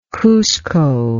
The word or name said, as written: Cuzco